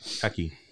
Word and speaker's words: Paqui: How do you pronounce this? – Paqui. – P-A-Q-U-I. – Like Pacquiao. It's like Pacquiao, but just Paqui.